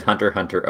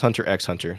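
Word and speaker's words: Hunter × Hunter: I said Hunter Hunter into Google was, is Hunter Hunter over? – Yeah, and it's not pronounced Hunter X Hunter, so just say Hunter Hunter.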